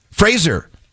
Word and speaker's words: Fraser: Fraser rhymes with razor. – Mm-hmm. – But a lot of people say, a lot of people think it's Fraser. It's not, it's Fraser.